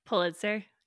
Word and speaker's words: Pulitzer: She is a Pulitzer Prize winning, is it Pulitzer? Is that how you pronounce it? – Pulitzer, yeah.